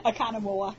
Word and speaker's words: Oconomowoc: Oconomowoc.